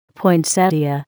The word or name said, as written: poinsettia